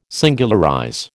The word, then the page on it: singularize: American English